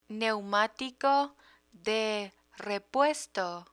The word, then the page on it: neumatico de repuesto: Spanish